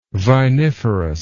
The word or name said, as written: viniferous